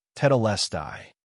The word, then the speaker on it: tetelestai: In Greek, it is pronounced tetelestai, meaning it is finished.